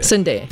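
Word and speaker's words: Sundae (순대): Sundae. – Yeah. – Did I say that right? – Yeah. Sundae. – Sundae. – Kama Sundae. – It's almost like Sunday.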